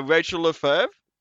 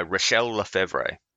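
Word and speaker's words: Rachelle Lefevre: but it also has a Rachel Lefebvre, I believe that's how you say her name. – No, Rachel Lefebvre.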